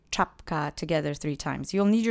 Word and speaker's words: czapka: Accent is on that first syllable, so really punch up the ch for me. Let's say it together three times. Czapka. Czapka. Czapka.